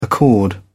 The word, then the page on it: accord: British English